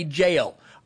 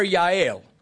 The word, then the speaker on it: Jael: her name is spelled J-A-E-L. We would say jail. I'm not, because I'm a sheriff's deputy, and that just causes me all kinds of mental issues. So I'm not going to call her jail. I will call her Yael, which is the Hebrew pronunciation.